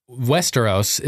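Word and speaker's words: Västerås: Westeros is the name of my hometown in Sweden. You pronounce it almost exactly like Westeros. It's V-A-S-T-E-R-A-S.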